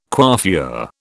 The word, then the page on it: coiffure: British English